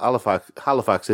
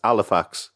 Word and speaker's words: Halifax: They never pronounce the H. So it's not Halifax. Yeah, so it's not Halifax, it's Halifax.